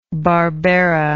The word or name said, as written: barbera